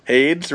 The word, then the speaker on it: Hades: And then AIDS. – AIDS? – AIDS. – Hades. – Hades, really? The same thing. – Really? – The closer pronunciation, I think, is Hades.